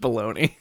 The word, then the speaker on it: Belloni: Puck.news writer Matthew Baloney, which I looked up, it's pronounced Baloney. – Is it really? – Yep. – Of course it is. – B-E-L-L-O-N-I.